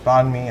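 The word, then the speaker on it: bánh mì: For example, their beans are directly sourced from Vietnam, and they also offer Vietnamese sandwiches called banh mi and other fine Vietnamese desserts.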